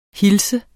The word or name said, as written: hilse